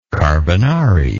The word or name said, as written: carbonarist